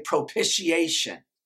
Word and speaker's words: propitiation: I can almost guarantee some of y'all didn't say it right. Because I have to say it slow to say it right. Everybody say that with me. Say propitiation.